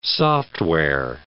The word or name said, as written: software